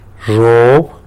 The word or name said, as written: zhub